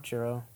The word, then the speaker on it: Chirrut: Chiro. – Chiro. – Actually, that sounds right because it's spelled C-H-I-R-R-U-T. So the U-T is...